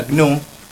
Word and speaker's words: GNU: Entonces GNU, que en realidad se pronuncia GNU, significa GNU no es Unix.